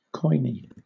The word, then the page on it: koine: British English